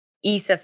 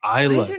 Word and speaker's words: Isla: Isla. God damn. – Isla. Why does she have that S there? – It's silent. – Oh, I didn't know. – The S is silent, girl. – It's like island.